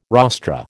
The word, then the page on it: rostra: American English